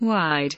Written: wide

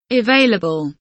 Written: available

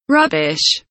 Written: rubbish

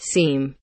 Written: seem

